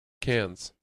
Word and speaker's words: Cairns: Cairns.